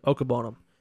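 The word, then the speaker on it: Okwuegbunam: Somebody else stepped in last year because Albert Okwobonam wasn't playing well. – Yeah. Either way. – That's how you pronounce the name, by the way. – What is it? – Okwobonam.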